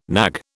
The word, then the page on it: knag: British English